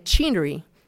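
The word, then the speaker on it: Chenery: if anybody comes in here saying chinery, which is how the name is normally pronounced here, he said, let them in right away, because they're from Virginia, and I want to see them.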